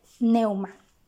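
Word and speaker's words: pneuma: La primera palabra es neuma. Si puedes, anótala en tu diario devocional. Se escribe P N E U M A y significa literalmente viento o aire en movimiento.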